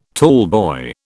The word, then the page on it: tallboy: British English